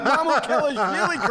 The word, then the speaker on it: Mamukelashvili: Bill, you said it right, Bill. You said Mamukelashvili correct.